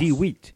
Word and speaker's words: Dhuit: D-H-U-I-T. – D-H-D-I-U-I-T. U-I-T. – Yeah. – That is also almost wheat in the French language, you know. – Yes. – D-Wheat.